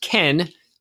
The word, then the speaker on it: Kean: I'm talking about Moise Kean. Apparently, it's pronounced Ken in Italian.